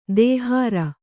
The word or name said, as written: ದಹರ